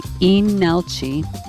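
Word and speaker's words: Leenalchi: It's spelled like you would say Linalchi, but the L is silent. Inalchi.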